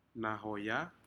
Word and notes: nahoya: Aneityum

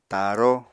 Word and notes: taaró: Apatani

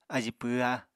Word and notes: ají pwhá: apatani

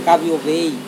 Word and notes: kaviovei: Serewen

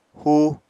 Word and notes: húu: apatani

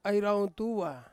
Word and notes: airauntua: Ansus

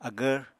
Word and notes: agvr: Apatani

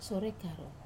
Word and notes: sore karou: Wamesa

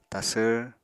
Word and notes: tasvr: Apatani

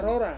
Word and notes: rora: Ansus

